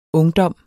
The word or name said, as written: ungdom